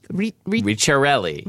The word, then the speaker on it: Ricciarelli: Ricciarelli. – Ricciarelli, which are almond cookies.